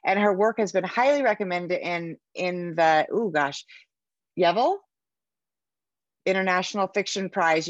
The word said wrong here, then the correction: Yeovil: And how do you pronounce that? – It's Yeovil.